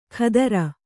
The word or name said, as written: ಖದರ